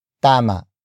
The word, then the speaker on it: tama: Tama. Tama. Tama is spelled as T-A-M-A.